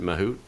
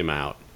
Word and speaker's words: Mahout: that the Hindi pronunciation is Mahavit. The folks who are from India tend to pronounce it Mahout, so that's what I do. But a lot of people say Mahout. And there is no answer.